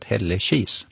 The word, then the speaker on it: Hällekis: Och slår man upp Hällekis då, så står uttalet Hällekis.